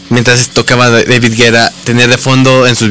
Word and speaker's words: David Guetta: Dije, sí, no alcancé a ver Pitbull, no voy a intentarlo con David Guetta, que le digo a María Pau que es la manera correcta de pronunciarlo. – En Francia, sí, en su país natal, se dice David Guetta, es correcto.